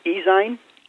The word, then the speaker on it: ezine: How do you pronounce it? It's Ezine? – Ezine. – Ezine. I can never pronounce it. As in magazine.